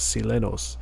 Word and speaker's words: Silenoz: I used to call him Silenoz every time, and then I uh, finally heard him say it in an interview somewhat recently, and that's how he pronounced his uh, his stage name was Silenoz.